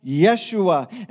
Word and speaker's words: Yeshua: He didn't hear the Greek word because that's the transliteration of the Hebrew word that comes from the word Joshua that's pronounced Yeshua.